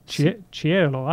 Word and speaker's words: Cielo: C-I-E-L-O. How would you pronounce that, David? Cielo? – Cielo.